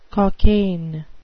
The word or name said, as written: cockaigne